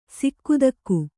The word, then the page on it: ಸಿಕ್ಥಕ: sikthaka